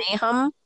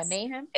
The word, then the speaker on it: Nahum: Nahum. I hope that I'm pronouncing it right. – Nahum, maybe Nahum. – Nahum. Yes. Yeah, Nahum.